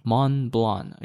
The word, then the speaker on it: Montblanc: Montblanc. Okay, you don't pronounce the T and the C.